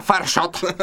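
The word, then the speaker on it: Fire Shot: Y se pronuncia Farshot. Que es en inglés, pero no importa. – Farshoto.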